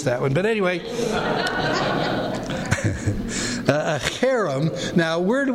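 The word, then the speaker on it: cherem (חרם): Not harem, but cherem. Looks like this. C-H-E-R-E-M. Okay, so this is the letter H here, there. H, and then we have a short E, and then we have an R, another short E, and then M.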